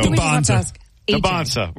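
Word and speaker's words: Dybantsa: I say DeBonsa, DeBonsa. – I know. Can we get one pronunciation, please? Because I've heard it both ways for 2 years. So DeBonsa. – We're going DeBonsa.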